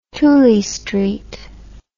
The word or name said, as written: tooley street